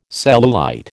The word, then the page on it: cellulite: American English